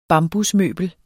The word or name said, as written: bambusmøbel